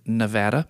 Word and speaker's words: Nevada: And by the way, they pronounce it Nevada.